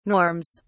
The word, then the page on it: norms: British English